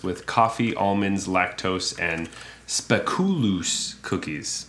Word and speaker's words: speculoos: According to Google, it's speculoos. – Speculoos. Weird. It doesn't look like that. But also, whatever. Okay, so that feels like a bastardization of the spelling. – Speculoos. – Like it dinged that I'm from North America, and it's like, it's pronounced speculoos.